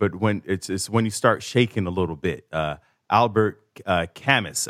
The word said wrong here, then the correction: Camus: I don't think that's how you pronounce it. I think it's Camus, C-A-M-U-S.